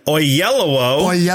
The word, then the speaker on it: Oyelowo: And David Oyelowo, which I spelled wrong here, but pronounced correctly.